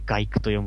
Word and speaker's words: 街区: 町に 23 区の区と書いて外区と読むんですけど。